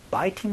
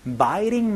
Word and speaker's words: biting: I have a habit of biting my nails. biting이라는 그 T 소리는요. 모음 소리 가운데서 biting my nails, biting 이런 식으로 굴려서도 발음하는 경우가 많으니까 이것도 같이 알아두시면 좋겠습니다.